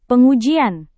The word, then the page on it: pengujian: indonesian